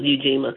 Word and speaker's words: Ujima: Did you say Ujima? – Ujima. Okay. I just want to make sure I was pronouncing it correctly. And, you know, it's like it's like the Kwanzaa principle, Ujima.